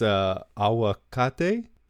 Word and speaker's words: aguacate: It's aguacate.